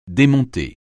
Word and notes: démonter: français

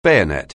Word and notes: bayonet: anglais

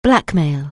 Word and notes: blackmail: anglais

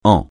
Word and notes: en: francais

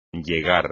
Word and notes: llegar: Spanish

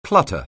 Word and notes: clutter: anglais

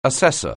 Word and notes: assessor: anglais

